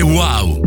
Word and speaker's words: UAU: Si scrive UAU, si legge wow.